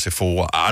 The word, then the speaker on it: Sephora: først der, min hjerne har aldrig nogensinde brugt tid på at skulle spekulere over, hvordan udtales S-E-P-H-O-R-A. Mm. Sephora og Sephora, I don't know. – Men det var selvfølgelig Sephora.